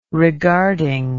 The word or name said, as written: regarding